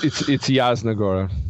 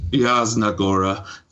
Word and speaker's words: Jasna Góra: All my Polish-American friends, I apologize, mea culpa, but I am mispronouncing that. But it's Jasna Góra. – Jasna Góra. Thank you. Jasna Góra.